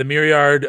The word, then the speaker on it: Maillard: I don't think that they're going to say it tastes like a burger because what's the Maillard? Is that how you say it? When the meat? – Yeah. The Maillard.